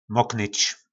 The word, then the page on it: moknyc: po našimu